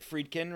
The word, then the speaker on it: Friedkin: Is it Friedkin, right? Is that how you say it? – Yeah.